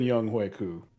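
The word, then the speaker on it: Younghoe Koo: What the hell is a Young Hwe? – His actual pronunciation of his name. – All right. Well, I read it as Young Ho, and that's what he gets to be called.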